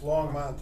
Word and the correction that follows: Longmont: if anyone asks. – Yeah. – Am I saying it wrong? – Yeah. – What am I saying? – Longmont sounded like. – And it's Longmont? – Yes.